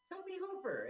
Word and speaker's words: Tobe Hooper: Toby Hooper. Am I saying it wrong? – No, I think you're right. I think it is two syllables.